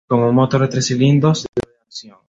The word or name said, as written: doble